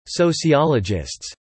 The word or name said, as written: sociologists